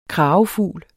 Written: kragefugl

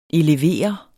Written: elevere

